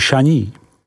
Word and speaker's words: Chagny: Côte du Beaune, vyslovko du Beaune, se rozkládá od hranic obce Echevrone, vyslov Echevron, po Chagny, vyslovuj Šaní.